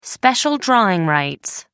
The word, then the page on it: special drawing rights: American English